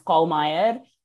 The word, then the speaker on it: Calmeyer: Actually, so the, the name, the last name Calmeyer is actually German. It's Calmeyer.